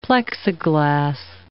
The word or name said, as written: Plexiglas